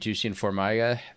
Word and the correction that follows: Jussier Formiga: at uh, Jussier Formiga. I'm sorry, I'm working my pronunciations after you gave Formiga, me.